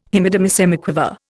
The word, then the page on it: Hemidemisemiquaver: Indian